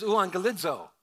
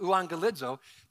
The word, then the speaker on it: euangelizo: You pronounce the two gammas here. It's like a G sound. When there's two of them, it has an NG sound. So this is euangelizo. It's how you say it, euangelizo.